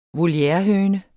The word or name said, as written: volierehøne